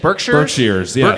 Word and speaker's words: Berkshires: Berkshires.